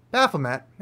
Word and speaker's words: Baphomet: Baphomet, as I like to pronounce it, because the two pronunciations are equally correct, but I believe Baphomet's better because it does come from the French and there's no reason to call it May.